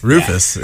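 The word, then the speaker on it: Rüfüs: Cody's going to play Sundream by Refuse de Soul. – Rufus yeah. is how you say it.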